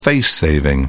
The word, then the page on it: face saving: British English